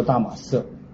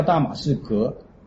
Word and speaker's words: 大马色: 讲到大马色依照原文的发音或者希伯来文的发音都是中东语言，他念出来这个城市叫做大马色。那你说不对啊，那明明是叫大马士革，这圣经翻错了，翻成大马色。